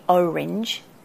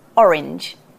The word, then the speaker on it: orange: So I've heard a lot of Americans say orange. It's not. It's orange.